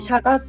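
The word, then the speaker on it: 著莪: シャガで漢字で書くとあの著書の著、うん、著作の著に「あの我」っていう字の上に草冠をつけて「が」って読ませるので「しゃが」って読むんだけど、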